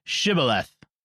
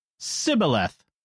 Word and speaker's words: Shibboleth: When he said, 'No,' they said to him, 'Then say Shibboleth.' And he said, 'Sibboleth,' for he could not pronounce it right.